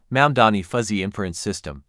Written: mamdani fuzzy inference system

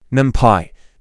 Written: NumPy

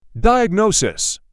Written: Diagnosis

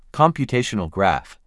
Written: Computational graph

